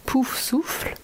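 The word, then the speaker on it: Poufsouffle: Hey, so the correct French pronunciation of the Hogwarts houses, we have Gryffindor, Ravenclaw, Sort d'Aigle, Hufflepuff, Pouf Souffle, and Slytherin, which is Serpentard.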